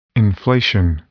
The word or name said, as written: inflation